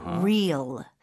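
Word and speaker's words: Reels: R 발음, 혀가 이제 꼬부리면서 강한 느낌으로 해야 돼요. Real, real. – 오, 스펠링이 어떻게 되나요? – R-E-E-L. 이거 R-E-A-L 하고 발음이 똑같아요.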